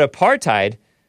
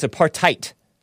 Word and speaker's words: apartheid: But apartheid, or it's actually, I heard it's pronounced apartheid.